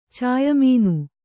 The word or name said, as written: ಚಾಯಮೀನು